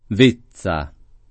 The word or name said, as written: Vezza